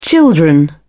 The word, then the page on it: children: British English